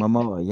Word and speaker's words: Momoa: They're I think I was saying Jason Momoa it eh, wrong too. – And no, I you apologize. no, I think we that is correct. That right. Momoa, yeah. Jason Momoa, you got that.